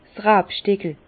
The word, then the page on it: 's Rabstickel: Bas Rhin; Herrlisheim